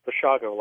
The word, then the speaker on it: Bushago: It's Bushago, like Chicago.